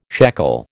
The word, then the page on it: shekel: American English